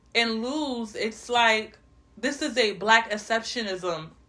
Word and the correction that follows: exceptionalism: Did I say it right? I think I didn't say it right. A black exceptionalism problem.